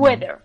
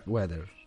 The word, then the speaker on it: whether: Weather, también antes de nada hay que tener cuidado con el spelling, con la forma de escribirlo, porque hay muchas veces suena igual que weather de tiempo, pero significa sí, o sea, un sí no afirmativo. Mm. Se escribe W-H-E-T-H-E-R, mm, weather. weather.